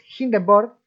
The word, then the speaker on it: Hindenburg: Y llega Hindenburg, que la gente lo pronuncia mal, es Hindenburg, es el mejor editor para podcasters, editor de audio.